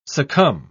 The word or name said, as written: succumb